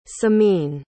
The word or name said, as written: semine